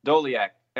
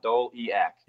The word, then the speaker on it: Doliak: It's D-O-L-E-A-C, right? – See? Yeah, Doliak. People make it harder than it actually is. You just sound it right on out. Doliak.